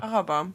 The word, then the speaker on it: Araber: Araber oder? – Araber. – Araber, das ist die Betonung auf dem ersten.